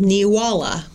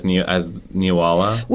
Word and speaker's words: Neewollah: And that town is called Independence, and its celebration is still around today, and it's called Neewala, which is spelled N-E-E. Walla. – N-E-E-W-O-L-L-A-H, which is Halloween backwards.